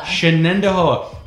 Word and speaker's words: Shenandoah: Shenandoah.